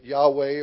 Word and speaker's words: Yahweh: And some, again, insistence on pronouncing it Yahweh or Yahweh.